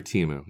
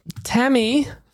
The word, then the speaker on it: Temu: Temu. That's right. Say it right. You're wearing the shirt. – Even Pete screwed that up. – Temi. – If you're watching the video, the sweatshirt, Temu.